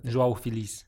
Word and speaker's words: João Félix: João Félix.